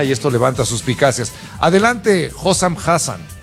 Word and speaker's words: Hossam Hassan: Hossam Hassan.